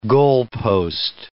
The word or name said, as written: goalpost